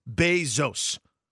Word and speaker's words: Bezos: But it definitely says Bezos. – Correct. – For pronunciation stuff. So I've always just gone with that.